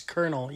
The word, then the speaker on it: colonel: Colonel? – Yeah. No, it's Colonel. – No, it's Colonel. You pronounce it Colonel. – C-O-L-E-N-A-L.